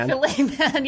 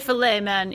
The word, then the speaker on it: Fils-Aimé: Filet Man. – Yes. – Okay. – Legendary Filet Man.